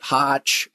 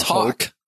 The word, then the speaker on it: Hoch: He's fantastic, but it's spelled H-O-C-H. And so most people pronounce it Hotch or Hoach. It's Hawk. Or Hawk. It's Justin Hawk. So get it right.